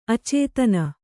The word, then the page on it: ಅಚೇತನ: acētana